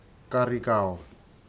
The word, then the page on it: karikau: futuna aniwa